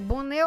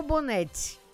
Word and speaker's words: Bonet: Escreve B-O-N-E-T. Pode ser Bonnet ou Bonnette, né? Alguns têm diferença de pronúncia.